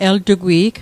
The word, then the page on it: eltugwi'g: el'tukwi'k; Mi'gmaq